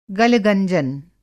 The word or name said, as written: गलगंजन